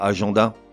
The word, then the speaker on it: agenda: Non, pourquoi agenda Certes, ça s'écrit A-G-E-N-D-A. E et certes, les lettres E-N devant un D devraient se prononcer en. Mais pas là, ça se prononce agenda.